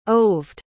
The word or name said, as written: Oved